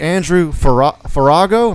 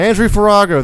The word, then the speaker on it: Andrew Farago: We have here with us today Andrew Farago. Am I saying that right? – You're absolutely right. – Perfect. Andrew Farago, the curator of Cartoon Art Museum in San Francisco, where he has curated over 100 exhibits.